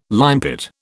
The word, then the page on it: limepit: British English